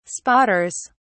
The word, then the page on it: spotters: British English